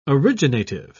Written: originative

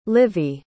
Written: livi

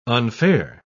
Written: unfair

